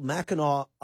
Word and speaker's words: Mackinac: I've seen it spelled with an AC at the end. So I don't know. I guess that's how they pronounce it. I've gone through several pronunciations of this thing. I think most people from Michigan pronounce it Mackinac Island.